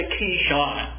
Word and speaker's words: Kishon: and what we're going to do is kill them there at the Kishon. That is how you pronounce that in Hebrew. The Kishon Brook.